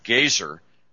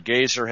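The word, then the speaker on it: Goeser: Gaiser, no longer living.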